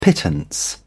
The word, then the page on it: pittance: British English